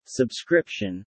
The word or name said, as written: subscription